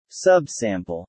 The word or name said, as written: sub-sample